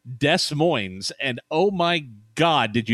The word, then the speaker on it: Des Moines: So, hey, real quick, I wanted to say on the January 1982 episode, I evidently pronounced Des Moines, Iowa, very, very wrong. It is Des Moines, as I know.